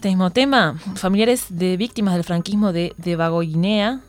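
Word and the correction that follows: Debagoiena: a ver, lo pronuncié mal, ¿no? – De Bagoyena.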